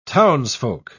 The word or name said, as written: townsfolk